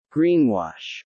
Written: greenwash

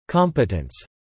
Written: competence

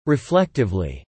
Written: reflectively

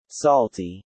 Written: Salty

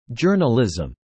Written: journalism

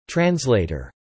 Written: translator

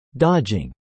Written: dodging

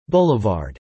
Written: Boulevard

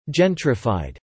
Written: Gentrified

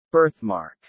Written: birthmark

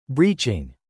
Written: breaching

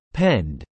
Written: penned

